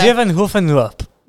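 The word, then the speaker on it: Zevenheuvelenloop: Zevenhuvenloop. – Yeah, the Zevenhuvenloop. Yeah. Oh, yeah. Almost there. Yeah. – Zevenhuvenloop. – Not Hoofen, Hevel.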